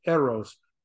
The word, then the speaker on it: eros: I pronounced it right. It's eros, which is talking about romantic and physical love, the passionate love that you have with husband and wife.